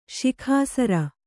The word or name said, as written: ಶಿಖಾಸರ